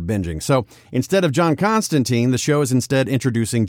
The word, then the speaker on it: Constantine: Also, we are getting the actual pronunciation as Constantine, as originally intended.